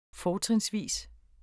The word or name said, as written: fortrinsvis